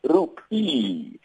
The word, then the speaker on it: rupee: Just to correct you, Potetswa, it's rupee. The Indian rupee. That's how you pronounce it.